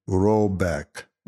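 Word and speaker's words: Råbäck: The manor house, or Ergard, in question is to be called Hreibach, pronounced something like Robeck, though that is not its name.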